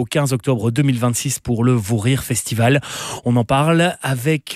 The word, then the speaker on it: Vous Rire: On va parler maintenant du Vous Rire Festival qui a changé de nom mais pas vraiment de prononciation puisque ça s'appelle toujours le Vous Rire mais ça s'écrit V-O-U-S maintenant.